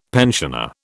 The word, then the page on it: pensioner: British English